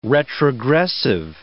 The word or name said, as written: retrogressive